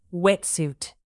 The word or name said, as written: wetsuit